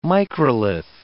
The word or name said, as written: microlith